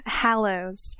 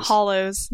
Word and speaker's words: Hallows: Yeah, Hallows. – Hallows. – Hallows. – Hallows. – Not hollows. Not hollows. Hollow, like a hollow in a tree.